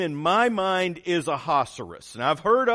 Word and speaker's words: Ahasuerus: But Esther will call him Ahasuerus, which rhymes with rhinoceros, the whole book.